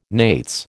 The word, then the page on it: nates: American English